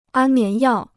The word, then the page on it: 安眠药: ān mián yào